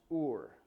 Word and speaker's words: Ur: Place was it's written "you are," but it's pronounced "Ur."